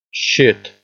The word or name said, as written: shit